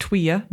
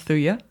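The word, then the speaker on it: thuja: Well, my first intrusive thought is I've just learned that the wood is pronounced Tweya, not Thuya, so.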